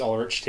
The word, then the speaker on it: Ulrich: Am I saying that correctly? – I've never really been sure myself. I think it's Ulrich too.